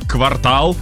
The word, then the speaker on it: квартал: Слово «квартал» так. – произносится как «квартал» как в любом случае. – Не «квартал». – Да. Да. «Квартал», а «квартал», он всегда «квартал».